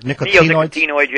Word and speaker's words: neonicotinoids: Part of the difficulty here is the fact that the manufacturers of nicotinoids, I guess is the proper way to say it. Is that correct? – Neonicotinoids, yes. – Yeah, neonicotinoids.